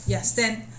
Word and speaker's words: 逸仙: 那他逸仙，孙逸仙是因为广州，因为国父是广东人，然后他广东话是也森，所以才会孙逸，对也森。